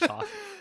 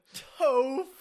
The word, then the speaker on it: Toph: So you do now know that it's actually... – It is actually Tuff. – Tuff. That was so good. I was like... – I think I figured out what the problem is. In the subtitles, they spell it T-O-P-H. – Uh-huh. What is T-O-P-H? – Right. – T-O-P-H? – T-O-P-H. – T-O-P-H. – And so my instinct is, oh, it's like short for Topher or something like that.